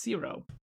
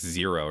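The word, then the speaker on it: CERO: It's zero. – Right. Is that how you pronounce it? I didn't know if it was pronounced something like zero or something cool. – Yeah, I think it's just zero. Zero.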